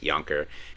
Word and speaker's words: Yonker: And so, Travis Yonker did this, and so that's where I kind of found it that they pronounce it Yonker. Apparently, his dad was born in the Netherlands. They moved in, so in Dutch, it's Yonker.